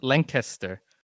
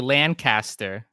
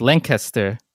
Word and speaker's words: Lancaster: And that's how you say it, Lancaster. It's spelled, it looks like Lancaster, right? Exactly how you would think Lancaster would be pronounced, but it's Lancaster, Pennsylvania. And if you say it wrong, the amount of mocking and ridicule that's directed your way is it's really undeserved.